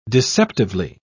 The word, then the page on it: deceptively: British English